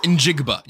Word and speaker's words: Njigba: And I say his name wrong every time. Jackson Smith Nigba? – No. Njigba. You can call him JSN now.